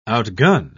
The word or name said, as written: outgun